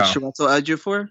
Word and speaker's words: Chiwetel Ejiofor: Chiwetel Ejiofor? – Yes, thank you.